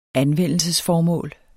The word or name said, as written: anvendelsesformål